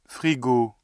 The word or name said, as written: frigo